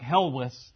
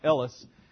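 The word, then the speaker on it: Helwys: So Smith ends up abandoning the project that he started, but he had a co-laborer named Thomas Ellis, which is spelled a little different. Looks like Helwis to us, but that's probably pronounced Ellis.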